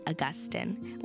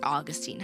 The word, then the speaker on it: Augustine: With that, let's delve into our topic for today, which is all about St. Monica and St. Augustine, or Augustine, however you prefer to pronounce it.